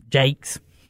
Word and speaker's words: Jacques: And we got a great artist, Doug Jakes, who's a South Austin Jacques is how it's spelled, but it was a South Austin Jakes, the way he pronounced it.